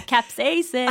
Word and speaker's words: capsaicin: Yeah, capsaicin.